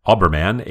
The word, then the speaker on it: Aweberman: My username is Auberman, A W E B E R M A N, and I would be happy to correct it.